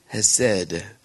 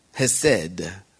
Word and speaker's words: Chesed: Hesed. Remember? C-H-E-S-E-D. Hesed. The C is silent. Mercy in Hebrew.